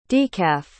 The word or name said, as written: decaf